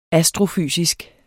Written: astrofysisk

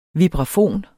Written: vibrafon

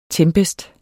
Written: tempest

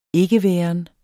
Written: ikkeværen

